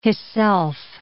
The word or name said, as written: hisself